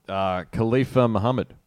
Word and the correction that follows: Khalifani Muhammad: Khalifani Muhammad. Good effort, though.